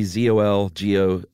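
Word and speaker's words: Czolgosz: I would say Zolgos. – That's pretty good. Gozar. – Silent C, Zolgosz.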